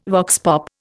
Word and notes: vox pop: Indian

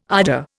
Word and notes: ardour: Indian

